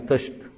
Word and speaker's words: طست: طست اللي هو إناء كده زي تقول عليه طشت، احنا نوع طشت تنطقها بالشين، لكن هي الفصحى بتاعتها تنطقها بالسين، تقول بطست، المصريين عليها بطشت يخلوا السين شين، هي بنفس المعنى.